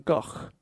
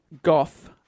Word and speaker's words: Gogh: So his real name is Vincent van Gogh. Like a sound at the end, but then no one says that in English, so more people say Goff than Go, so I think Goff is more correct.